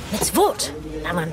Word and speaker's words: mitzvot: Well, I know there are four mitzvahs. No, mitzvot. That's how you say it.